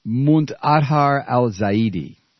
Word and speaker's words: Muntadhar al-Zaidi: What about Muntahar al-Zaidi? Muntaha, how do you say his name? You speak Arabic. Muntadhar al-Zaidi.